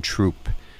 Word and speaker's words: Throop: These pieces of land are located on Underwood Road in Troop. So if you're driving on Interstate 81 and you see T-H-R-O-O-P, it's pronounced Troop for all you New Yorkers out there, right next to the Mid-Valley Middle and High School.